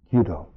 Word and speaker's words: Kyudo: He had practiced, I think it's Kyudo. How would you pronounce it? Kyudo.